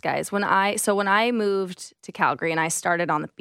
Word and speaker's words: Calgary: It's Calgary, not Calgary. I'm going to help you out.